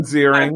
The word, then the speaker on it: Ziering: Only Ian Zierling can bring us together. – That's right. So I believe it's pronounced Ian. – Oh, I'm sorry. – Ian Ziering.